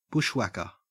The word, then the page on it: bushwhacker: Australian English